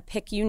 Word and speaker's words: PICU: You are a badass, and you have this amazing career, and you are a PICU nurse.